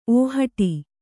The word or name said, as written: ಓಹಟಿ